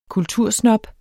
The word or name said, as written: kultursnob